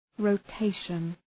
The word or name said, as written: rotation